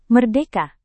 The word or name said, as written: merdeka